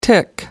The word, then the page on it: tic: American English